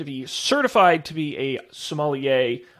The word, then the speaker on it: sommelier: We're going to be sommelier.